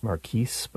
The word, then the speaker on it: marquis: And sometimes it's pronounced marquis, but it's spelled M-A-R-Q-U-I-S,